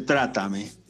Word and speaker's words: trátame: Pero La canción, la canción tiene acento en la A, ¿eh? Ahora que, la, ahora que la veo, aquí dice trátame.